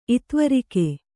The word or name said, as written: ಇತ್ವರಿಕೆ